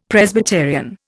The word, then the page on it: Presbyterian: Indian